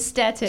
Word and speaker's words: Stetten: How do you pronounce it? Stetten? – Stetten. – Stetten. What did I say? Did I say Stetten? – You said Stetten.